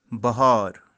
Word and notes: bahaar: kashmiri

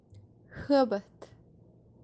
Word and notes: habath: kashmiri

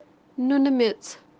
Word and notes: noonumets: kashmiri

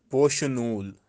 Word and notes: poshi-nool: Kashmiri

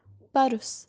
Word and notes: parus: Kashmiri

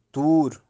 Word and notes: Tur: toor

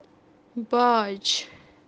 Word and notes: baaj: kashmiri

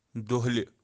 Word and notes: dohli: kashmiri

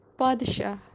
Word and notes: paadshah: Kashmiri

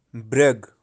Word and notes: Brag: Kashmiri

